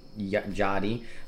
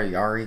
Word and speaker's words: Jotty: Came over, so uh, shout out to uh, Sean, AJ, Matt, Deirdre, and Jotty. I keep trying to call her Yari because that's how it's spelled, but it is Jotty.